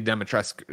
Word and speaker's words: Dimitrescu: Like, that was for, you're talking about Lady Dimitrescu. Did I say that right? Yeah.